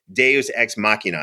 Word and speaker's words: deus ex machina: So the Eye of Thundera was directly activated and came to life in many episodes of the Thundercats and was their deus ex machina.